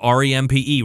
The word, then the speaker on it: Rempe: Just like my last name, R E M P E, Rempe, not Rempke. There's no K in my name. My daddy didn't put a K in my name. Say it right, Rempke. It's not that Rempy. Say it that. And it's not like Ren and Stimpy.